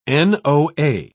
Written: noa

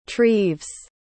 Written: treves

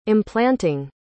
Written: implanting

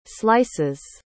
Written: slices

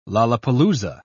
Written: lollapaloosa

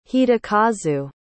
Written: hidekazu

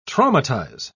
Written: traumatize

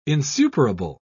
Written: insuperable

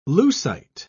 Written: leucite

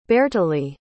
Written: beartlaidh

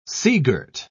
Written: seagirt

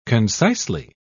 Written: concisely

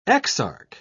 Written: exarch

